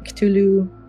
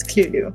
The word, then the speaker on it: Cthulhu: Yeah, everybody says Cthulhu, but it's Cthulhu, and Lovecraft actually stated, like, he answered that question very specifically, how to say it, and it's Cthulhu. – Cthulhu.